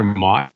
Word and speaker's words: Motte: I don't know, but like when you brought back Tyler, is it Tyler Motte?